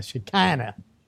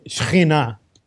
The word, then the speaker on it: Shekhinah: I guess here in Texas, they would call it the Shekinah, yes. Right? The Shekinah glory. But of course, we say Shekhinah, and I know not everybody can pronounce that, so.